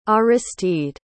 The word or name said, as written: aristide